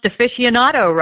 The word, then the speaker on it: aficionado: I mean, she even pronounced aficionado right.